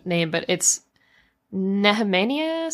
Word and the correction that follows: Nehemia: something? – Princess Nehemia. Nehemia.